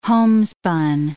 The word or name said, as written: homespun